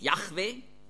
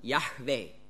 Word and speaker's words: Yahweh: J-A-H, ja, genau. Ja, ja. Ja, und das H sollte eben hörbar sein. Halleluja. Ja, so wie Yahweh sollte man eben aussprechen mit einem hörbaren H. Nicht ein CH, nicht Yahweh, ja. Wie manche sagen, das ist falsch, sondern Yahweh, also mit hörbarem H.